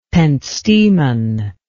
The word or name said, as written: pentstemon